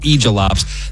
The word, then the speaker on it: aegilops: It's spelled A-E-G-I-L-O-P-S, pronounced egilops.